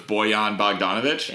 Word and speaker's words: Bojan Bogdanović: I'll start it here with my number one value pick, which was Bojan Bogdanović.